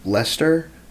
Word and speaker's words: Leicester: I have Man City over Leicester.